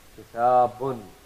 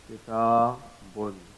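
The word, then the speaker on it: Kitabun (كِتَابٌ): So we are pronouncing the sound of a noon which is sakin. Kitabun, as if we are pronouncing it like this. Kitabun. Isn't that right? Kitabun. But we are not writing the noon. Tasbutu lafzan, it is pronounced, but it's not written.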